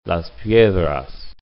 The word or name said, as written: Las Piedras